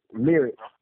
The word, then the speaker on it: Lyrik: My name is spelled L-Y-R-I-K with a K, not a C. Lyric with a K, not a C. L-Y-R-I-K.